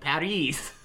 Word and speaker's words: Paris: Oh, University of Paris. – You said it right, actually. You said you don't say the S.